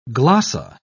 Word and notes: glossa: British English

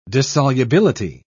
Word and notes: dissolubility: British English

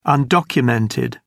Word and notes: undocumented: British English

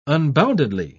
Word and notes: unboundedly: British English

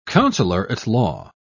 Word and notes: counselor-at-law: British English

